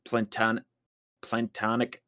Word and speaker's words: planktonic: It's plankton. I was saying it wrong. – Planktonic. – It is planktonic eggs or larva, but it's, I thought it meant something else, but no, it's just plankton. Another